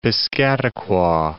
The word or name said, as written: Piscataqua